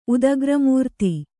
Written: ಉದಗ್ರಮೂರ್ತಿ

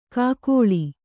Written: ಕೂಗಳಿ

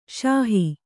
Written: ಸಾಹು

